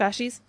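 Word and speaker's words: fasces: That's what the fa- uh, the fa- what, is it, what is it fascist? Is that how you pronounce it? Fascies. – The yeah. fascies.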